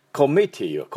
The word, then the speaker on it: committee: で、コミッティはこう、ミのところにアクセントがありますよね。コミッティ、コミッティというふうに発音してください。